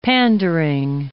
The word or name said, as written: pandering